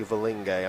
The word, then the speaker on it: Valinge: Five Valangi exactly. – dropping lock. – Is that English? – I don't know. Or I think it's actually Valinge. I might have mispronounced it.